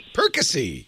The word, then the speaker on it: Perkasie: How do you say that? Perkasie? Perkasie, PA? – Perkasie.